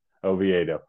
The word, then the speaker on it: Oviedo: Oviedo.